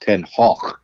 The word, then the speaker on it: Ten Hag: But anyway, to go back to Ten Hag, or actually his name in Dutch is pronounced Ten Hag, but no commentator is going to sound like he's about to expectorate when he refers by the correct pronunciation.